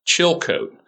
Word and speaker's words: Chilcote: Chilcote. – Just like it's spelled.